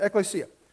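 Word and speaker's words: ekklesia (ἐκκλησία): And that's how you say it, ekklesia.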